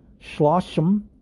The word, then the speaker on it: shloshim: So mourners mark the end of shloshim.